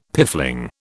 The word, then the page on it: piffling: British English